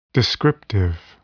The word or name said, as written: descriptive